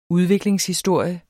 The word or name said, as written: udviklingshistorie